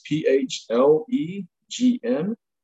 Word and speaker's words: phlegm: And he speaks of the blood, the phlegm, and I'm thinking I'm pronouncing that right. It's P H L E G M phlegm, yellow, which is the green bile, and black bile.